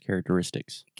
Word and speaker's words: characteristics: So the stress is on the penultimate syllable, the next to last syllable. And it's actually a double stress. Characteristics. Characteristics.